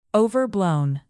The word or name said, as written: overblown